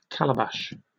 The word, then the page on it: calabash: British English